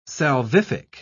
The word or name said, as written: salvific